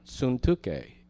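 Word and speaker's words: Syntyche: Suntuke is the Greek pronunciation.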